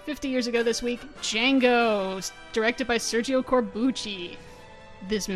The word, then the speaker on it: Django: If you saw Django Unchained, you remember that there's a scene where Django is standing at a bar with a guy with a foreign accent, and he says, "My name's Django, D is silent."